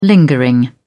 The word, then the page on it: lingering: British English